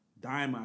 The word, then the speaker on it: Daima: So we all watched the trailer to the new Dragon Ball Daima. I think that's how you say it. – Yes.